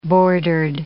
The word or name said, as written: bordered